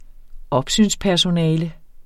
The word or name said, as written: opsynspersonale